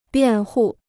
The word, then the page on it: 辩护: biàn hù